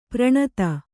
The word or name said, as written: ಪೌರನೀತಿ